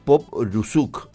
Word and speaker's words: Popruzuk: Estamos en comunicación con Adrián Popruzuk. ¿Así está bien pronunciado el apellido, Adrián? Buen día. – Buen día, buen día. ¿Qué tal? Gracias por el llamado. Sí, sí, casi así es el apellido. Bastante bien.